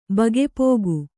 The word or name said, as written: ಬಗೆಪೋಗು